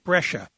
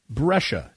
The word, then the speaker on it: Brescia: Brescia? Did I say that correctly? – Brescia. – Brescia. Thank you very much. It's like Patricia, CIA. Brescia.